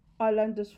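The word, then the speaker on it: Iyanla: Is that Yolanda's father? Yolanda's father? Ayanla's father. Oh my God. See, I can't pronounce her name.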